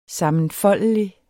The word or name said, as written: sammenfoldelig